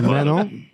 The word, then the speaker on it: Mano: A lot of people call me Manu, like a menu. No, it's mono, but yeah. – Isn't it Mano? Mano? – Mono. – M-A-N-O. It's spelled Manu, though, right? – No. M-A-N-O. – Oh. – Mono. – But full name, it's... – No. – It's like mononucleosis. – That's like M-A-N-U? – M-A-N-O.